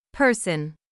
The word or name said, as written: person